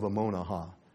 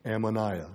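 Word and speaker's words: Ammonihah: Ammonaha, or Ammoniah. I'm not sure how you want to pronounce that. I've heard it pronounced both ways, but I call it Ammonaha.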